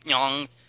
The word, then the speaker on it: ng: it's not a word, it's nyong, but that always trips up English speakers.